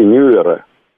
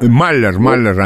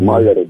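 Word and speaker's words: Mueller: И, в общем-то, расследование комиссии Мюллера или Моллера. – Маллер, американцы говорят. – Да, Маллер, да, там это не совсем папаша Мюллер, как в Америке произносят эту фамилию.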